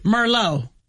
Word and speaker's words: Merlot: Merlot. – Yep. – It'd be Merlot. It says Merlot. – I guess so, yeah. – Yeah, remember Merlot is, and that T is silent on the end of Merlot.